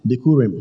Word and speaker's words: Bikurim: Bikurim. I don't know how you spell it, but try. Bikurim. Bikurim. It sounds like Bukum Lion. It's Bikurim. Now, Bikurim actually means a promise to come in the Hebrew.